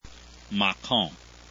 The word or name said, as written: macon